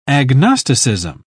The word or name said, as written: agnosticism